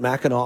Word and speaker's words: Mackinac: I've seen it spelled with an AC at the end. So, I don't know. I guess that's how they pronounce it. I've gone through several pronunciations of this thing. They think most people from Michigan pronounce it Mackinac Island.